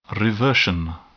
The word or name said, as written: reversion